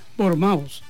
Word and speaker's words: Bournemouth: Pero ya dice, se pronuncia Bournemouth, así ya, para más rápido, Bournemouth.